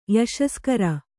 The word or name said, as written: ಯಶಸ್ಕರ